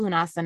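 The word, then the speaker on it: Lughnasadh: So I just want to share this is the big first fire festival, Lugnasa. If you, it's called Lunasa now um, because no one knows how to pronounce Gaelic.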